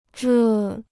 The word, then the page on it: 这: zhè